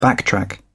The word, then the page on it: backtrack: British English